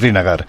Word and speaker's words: Srinagar: Es que la pronunciación de la capital es Srinagar. – Muy difícil. Es Srinagar.